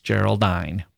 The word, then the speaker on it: Geraldine: It's about a woman named Christabel who's walking in the woods when she encounters a woman named Geraldine, or it seems Geraldine, pronounced like decline, who says she's been abducted by some men on horseback.